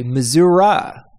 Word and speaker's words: Missouri: How do you pronounce the state that Kansas City, bigger Kansas City, and St. Louis are located in? – Missouri? – People in that state say Missouri. Missouri.